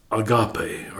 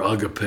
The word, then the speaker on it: agape: Then the one that Paul chose to use in 1 Corinthians 13 is agape, or agape. It's pronounced both ways.